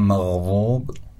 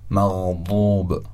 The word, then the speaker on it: مغضوب: یا کلمه مغضوب، اول میگم مغضوب، حالا مغضوب. کدوم وا بود کدوم واد؟ بعد اولی وا یعنی تنزین بود، خیلی نرم تلفظ شد نسبت به واد، و دومی حرف واد. مغضوب، غیر المغضوب علیهم.